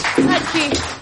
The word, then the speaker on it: Utke: Utke.